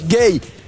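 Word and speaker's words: Guéhi: Gay. – Ah, su nombre. – Genera un poco de confusión porque se escribe Güeji. Pero le explicó él que la pronunciación es gay.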